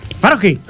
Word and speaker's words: Swarovski: Es como, es Swarovski. ¿Cómo se pronuncia eso? – Swarovski.